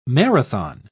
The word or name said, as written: marathon